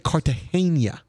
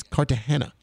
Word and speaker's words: Cartagena: Now I was told this morning on the radio that I was pronouncing it wrong. I said Cartagena, which is another beach town, but it's not Cartagena. It's Cartagena, allegedly.